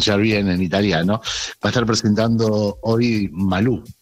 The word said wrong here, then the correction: Melú: Sí. Melú, perdón, no sé por qué desde hoy te estoy diciendo sí. Que es Malú, es Melú, Melú, así se llama el libro, Parcelas de Maternidad.